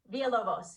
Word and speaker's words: Villalovos: So, Jamie, let me make sure I'm pronouncing your last name right. Villalovos? – Villalovos.